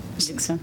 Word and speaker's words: Dixon: My name is David Dixon, sorry. – What? – Dixon. – Dixon? – D-I-X-O-N.